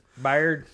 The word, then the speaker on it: Byard: And that's another thing, is like Kevin Byard, I think is his name. I think you're how to pronounce it. – Byard.